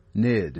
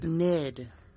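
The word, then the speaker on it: Gnid: It is done, and in KUVO Studios, drummer Reed Hoysen Project, the album called Home, and the opening track spelled G-N-I-D. I'm just going to go with NID. – NID. – With a silent G.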